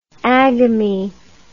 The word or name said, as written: agami